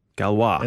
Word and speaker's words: Galois: DARPA has contracted with a company called Galois. – Galois. – And that's spelled G-A-L-O-I-S. – It's okay. – French mathematician. – Okay. – It's named after. Basically, what they're doing is they're developing two systems for voters.